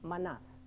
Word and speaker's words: manas: There is a word called, spelled like this, but it is pronounced manas. The accent is on the second syllable.